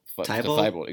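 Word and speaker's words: Thybulle: Matisse Thybul? – Thybul, exactly.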